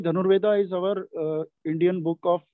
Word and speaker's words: Dhanurveda: And when you see the history in India, you know, with Dhanurveda, how do you pronounce it properly, the art of archery? There's this book, Dhanurveda. – It is Dhanurveda. – Dhanurveda, exactly. Dhanurveda.